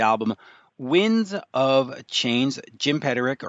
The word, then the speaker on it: Peterik: The UK in me wants to say Peterik, but the proper pronunciation. – Everybody from England says Peterik, and I go, it's Peterik.